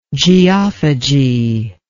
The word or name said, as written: geophagous